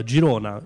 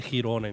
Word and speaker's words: Girona: Per esempio, prima abbiamo parlato di un ragazzo, Giuseppe, che ha fatto l'Erasmus a Girona. Io ho sempre pensato, prima di fare Eurofonica, che si chiamasse Girona, invece la pronuncia è catalana.